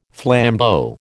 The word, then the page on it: flambeau: American English